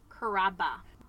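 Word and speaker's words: Carrabba: When he told me that Chris Cabrera, did I say that right? – Chris Caraba.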